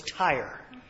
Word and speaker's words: Tyre: If you've been calling it Tyre, so have I. I always go with whoever the reader was, and Gene knows how to pronounce that, so it's Tyre.